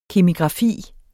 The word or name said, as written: kemigrafi